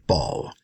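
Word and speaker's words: Bâle: Or the French pronunciation, Ball.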